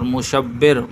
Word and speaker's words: مشبر: ان اسماء کا تلفظ لغت کی مشہور کتاب قاموس میں ہے کہ شبر بقمہ کے وزن پر، شبیر قمیر قمیرن شبیرن قمیرن کے وزن پر، اور مشبر